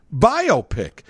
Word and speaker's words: biopic: Well, because, you know, and myopic is a word. – Right. – But that's MYOPIC. You would think probably it's pronounced the same way if you don't realize it's biopic. – That's why you need... The hyphen. If the hyphen's there, then you say biopic. You don't say biopic as if I just went to take a test and my doctor came in and said, your biopic is negative.